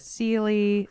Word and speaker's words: Sele: We don't pronounce it right. My family, it's not really Sele. – Was it like Sele? – Yeah, exactly. It's like, I can't really say it. I'd have to go to Norway and hear them say it,